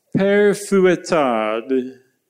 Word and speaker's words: Père Fouettard: This is, how do you pronounce that? You're the Frenchie here among us. – Père Fouettard. – That last D, that really finishes it off nicely.